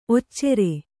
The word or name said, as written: ಒಚ್ಚರ